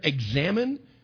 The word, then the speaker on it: Examen: He was a 16th century Jesuit priest, and he wrote what is called the Ignatian Examen. Now, if you pronounce that last word up there, examine, I'd be okay with that, because it's a way to examine our prayer life.